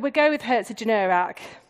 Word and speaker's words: Herzogenaurach: And the first story is set in a small German town called Herzogenaurach, if I've pronounced that correctly. Helen, does that sound right? Okay, we go with Herzogenaurach, which is home to a 60-year-old argument.